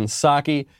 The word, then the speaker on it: Psaki: My favorite comment from Friday is from Eric Gomez, who says that the P in sake is silent. It's the same with the P in President Biden. This is very true. You have White House Press Secretary Jen Psaki,